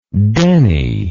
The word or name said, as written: Denny